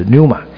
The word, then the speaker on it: pneuma: 希腊文用的那个叫做 pneuma，英文念是 P 不发音就是 pneuma，否则是 P-N-E-U-M-A pneuma。